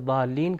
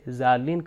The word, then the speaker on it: ضالین: تو یہ ضاد سے ضالین کا مطلب ہے وہ گمراہ لوگ، مگر اگر زے سے ضالین کہا جائے تو اس کا میننگ بالکل چینج ہو جاتا ہے، تو اس تلفظ کی طرف خاص توجہ رہے۔